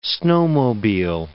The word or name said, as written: snowmobile